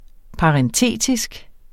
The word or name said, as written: parentetisk